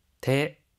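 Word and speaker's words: 테 (手): 테, 테. – 손수라고 하는 한자는 음으로는 슈, 뜻으로는 테라고 읽어요. 우리가 손이라는 뜻을 나타낼 때는 테라고 하죠. 이 손수를 사용하는 한자어 중에서 테로 읽는 단어 한번 연습해 볼까요?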